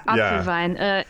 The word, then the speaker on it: Apfelwein: but Apfelwein. Apfelwein, is that the... – Oh, yeah. Apfelwein.